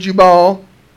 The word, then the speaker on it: Jubal: His brother's name was Jubal.